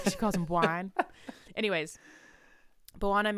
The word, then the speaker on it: Bwana: But Buwana, which is B-W-A-N-A, Buwana, I'm sure that's how you say it, right? – Buwana. Yeah, Buwana.